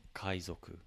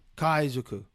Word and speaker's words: Kaizoku: We got to see Kaizoko. Is that how you say it? Was that how you say it? Do I look Japanese to you? So, my mistake, that's how, Kaizoku? – Kaizoku.